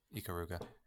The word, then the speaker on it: Ikaruga: Ikaruga. Is that how you say it? – Yeah. I mean, it's Japanese. – Okay. – Ikaruga.